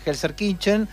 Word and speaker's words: Gelsenkirchen: Terminó 2-2 en un amistoso jugado en Gelsenkirchen, en la ciudad alemana.